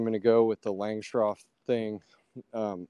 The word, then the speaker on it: Langstroth: They say Langstroth. – Yeah. – I think it's just Langstroth. That's how I say it. Okay. That's how it's spelled.